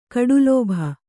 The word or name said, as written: ಕಡುಲೋಭಿ